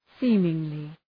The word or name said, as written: seemingly